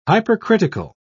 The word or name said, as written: hypercritical